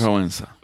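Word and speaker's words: Wałęsa: So just to wrap things up a little bit, hopefully on a high note, I saw a quote from Lech Walesa. Is that how you pronounce it? Walesa.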